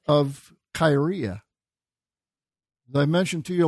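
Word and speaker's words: kyria (κυρία): or the word lady, kuria, in Greek, in modern pronunciation, it would be the word kyria.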